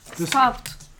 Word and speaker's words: stopped: But the pronunciation, because P and ED are hard to pronounce. – Stopped. – Excellent. – Stopped. – It's a T.